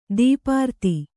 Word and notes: ಧೂಪಾರತಿ: dhūpārati

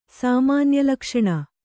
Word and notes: ಸಾಮಾನ್ಯಲಕ್ಷಣ: Kannada

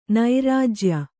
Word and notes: ನೈರಾಜ್ಯ: kannada

